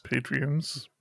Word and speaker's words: patrons: Huge thank you to our Patreons. Did I say it right? – No. – Patreons. – Patrons. – On Patreon.